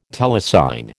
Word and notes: telecine: American English

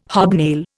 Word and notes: hobnail: Indian

